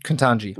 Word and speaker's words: Ketanji: Ketanji Brown Jackson? – Yeah, is that how you say her name? – Mm-hmm. Ketanji.